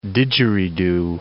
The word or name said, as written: didjeridoo